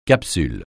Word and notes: capsule: francais

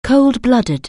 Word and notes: cold-blooded: anglais

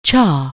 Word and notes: chapter: anglais

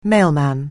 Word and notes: mailman: anglais

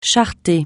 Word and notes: charretée: français

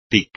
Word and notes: tic: espagnol

